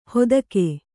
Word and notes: ಹೊತ್ತಗೆ: hottage

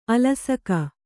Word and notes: ಆಲಸಿಕೆ: ālasike; Kannada

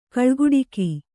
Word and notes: ಕಳ್ಗುಡಿಕ: kaḷguḍika; Kannada

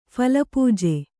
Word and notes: ಫಲಪೂಜೆ: phala pūje

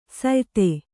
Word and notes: ಸ್ಯೂತಿ: syūti

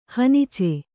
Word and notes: ಹನಿಚೆ: hanice

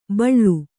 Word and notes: ಬಳ್ಳು: baḷḷu

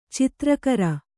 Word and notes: ಚಿತ್ರಗಾರ: Kannada

